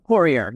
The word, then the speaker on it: Poirier: Yeah, Poirier, yes.